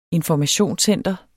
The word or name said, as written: informationscenter